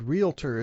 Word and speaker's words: realtor: Okay, well, the next one I have on the list, the word realtor is spelled R-E-A-L-T-O-R with no extra A between the L and the T there. – Mm-hmm. – But for some reason, in speech, people often put in an extra A, an extra syllable here, so they call it a realtor.